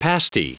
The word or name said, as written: pasty